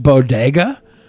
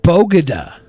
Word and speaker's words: bodega: It was bad enough she referred to Latino people as breakfast tacos, but the way she pronounced bodega was possibly worse. – Did she say bodega? – She said bogada. – Bogada. – A bogada. Didn't even say bodega would have been better.